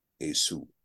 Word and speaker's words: Esau: Now, when you listen to Jewish sources talk about Esau, they do not say Esau. They say Esau. And we've talked about it before, that in fact, it's really not Esau, but it's rather Esau. Esau is really the correct pronunciation, Esau.